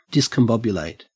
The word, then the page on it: discombobulate: Australian English